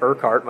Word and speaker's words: Erquhart: I am at Erquhart, my last name, and no one can spell it, but it's Erquhart.